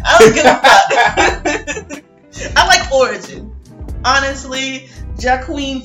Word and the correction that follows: Joaquin: Phoenix, or however you say his name. – Joaquin Phoenix. – Jaqueline. – Not Jaqueline.